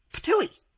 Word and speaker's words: Ptuj: Purse your lips and make a spitting sound like this. Ptui. That's the name. It's spelled P-T-U-J, but it's pronounced Ptui, and it's in Slovenia, the northwestern part of the former Yugoslavia.